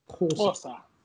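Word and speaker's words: Xhosa: I don't want to butcher the name of the language, but say it again. – Kosa.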